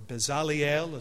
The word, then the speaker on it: Bezalel: Bezalel, or Bezaliel, as some people would pronounce it.